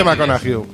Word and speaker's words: McConaughey: De toda la vida ha sido McConaughey. Y se escribe McConaughey.